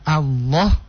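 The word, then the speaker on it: Allah (الله): Lafdul jalalah Allah ya. Allah itu dia ditebalkan. Fakhimil lama lam pada kata Allah itu lam di tengah.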